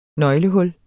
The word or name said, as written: nøglehul